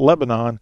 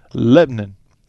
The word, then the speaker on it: Lebanon: Not Lebanon, Lebanon. That's how they say it.